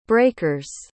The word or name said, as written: breakers